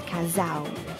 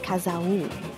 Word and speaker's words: casal: So like for example, couple is casal, so it's not casal-u, it's casal. Like the sound before, like the beginning of the diphthong is more stressed than the u. The u, you just close your mouth as you're pronouncing an u. So casal, casal.